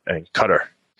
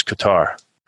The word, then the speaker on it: Qatar: And then we went to Iraq, Afghanistan, and Qatar. I always thought it was Qatar, but actually the right way to say it is Qatar, just so you know.